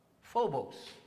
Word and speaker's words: phobos: The word that we find here, fear, in the New Testament is pronounced Phobos.